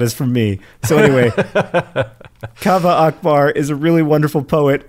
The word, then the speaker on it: Kaveh Akbar: On the most recent episode of the pod, you read a poem from, I'm going to say his name right now, Kaveh Akbar. I happened to go to grad school with Kaveh and was in a few classes with him. His name, you have been pronouncing it wrong, John. You have been pronouncing it wrong this whole time, even though you are a big fan of his.